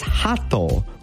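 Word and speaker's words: hato (鳩): The way to pronounce this is think of like laughing, right? So you go like ha, and then think of like, you know, toe. So like on your foot. So ha. Hato.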